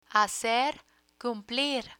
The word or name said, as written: hacer cumplir